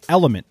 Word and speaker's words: LMNT: Today's show is sponsored and hydrated by Drink Element. That's spelled L-M-N-T, which I've been drinking daily now for weeks.